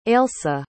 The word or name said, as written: ailsa